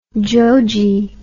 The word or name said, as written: jojee